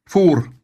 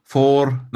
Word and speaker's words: for: det tre første bokstavene blir F-O-R. For. Uttales med Ø da. For. Nei, nei med Å.